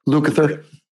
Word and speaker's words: Lukather: But I think a lot of the time, even today, somebody like Luke Lather, I can never say his name right. – Lukather.